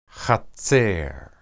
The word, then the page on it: hatser: hebrew